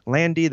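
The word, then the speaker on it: Landi: Landy, that's L-A-N-D-I, because I am Italian like that.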